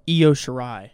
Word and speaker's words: Io Shirai: Oh, Io Shirai. – Oh, Io Shirai.